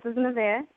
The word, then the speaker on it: Nevaeh: This is Nevaeh.